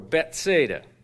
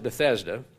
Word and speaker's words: Bethesda: Now, there in Jerusalem, by the Sheep Gate, there was a pool, which is called in the Hebrew Bethesda, or Bethsaida, probably is how it was pronounced over there, or closer to that. We pronounce it Bethesda.